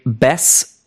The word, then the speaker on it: Bass: Das sind Brent Smith am Gesang, Barry Kirch, Schlagzeug und Percussion, Zach Myers, Leadgitarre und Gesang, Eric Bass, E-Bass und Gesang. Er wird wie Bass geschrieben, also wie der Bass, aber Bass wie der Barsch ausgesprochen. Genau, die Bandbesetzung gab es noch nicht immer bei Shinedown.